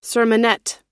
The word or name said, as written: sermonette